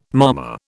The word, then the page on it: mama: British English